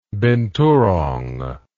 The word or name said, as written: binturong